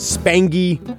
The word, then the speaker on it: Spangy: It is Spangy. I do not like when they mix the hard G with the soft G from the nickname to the actual name.